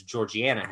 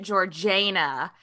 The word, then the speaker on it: Georgiana: Oh, it's so hard to say her name because it's spelled like Georgina. – It's spelled like Georgiana, but yeah. Apparently they pronounced it Georgiana, or at least the Cavendishes did, because they had like this really posh way of speaking, which.